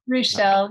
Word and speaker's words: Ruechel: Have I pronounced that right, Julius? – Ruechel. – Ruechel, yes, yeah.